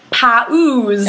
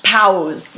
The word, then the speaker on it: pause: I think this is difficult for a lot of English learners, because when you have the two different vowels, like the P-A-U-S-E, the tendency is to want to pronounce each one separately, like pa-oos, right? I've heard this before anyway. I'm not just making things up. – I've heard it pronounced pows. – Pows, yeah. – Right, maybe I was exaggerating a little bit, but pows, yeah. So we kind of smush those vowels together and they get turned into one sound, paws.